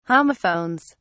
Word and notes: homophones: British English

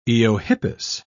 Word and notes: eohippus: British English

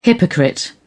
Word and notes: hypocrite: British English

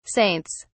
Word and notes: saints: British English

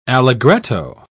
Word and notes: allegretto: British English

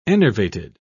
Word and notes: enervated: British English

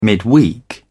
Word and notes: midweek: British English

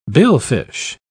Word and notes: billfish: British English

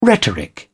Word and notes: rhetoric: British English

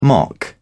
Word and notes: mock: British English

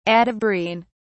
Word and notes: atabrine: British English

